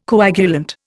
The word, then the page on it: coagulant: Indian